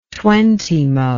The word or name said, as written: twentymo